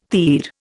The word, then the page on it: TIR: Indian